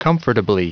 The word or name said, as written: comfortably